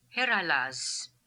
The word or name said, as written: Herailaz